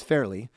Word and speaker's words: Fairlie: Anyway, this is coming to us, I believe the pronunciation is Fairly, F-A-I-R-L-I-E.